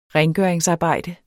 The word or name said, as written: rengøringsarbejde